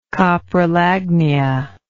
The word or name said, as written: coprolagnia